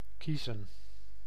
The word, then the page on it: choose: American English; [tʃuz]